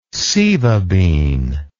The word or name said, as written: sieva bean